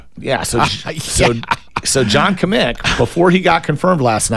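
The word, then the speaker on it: Kmiec: So it's Kamik, and it's K-M-I-E-C. – Right. – I would never guess Kamik. – Easy to pronounce, but spelled funny.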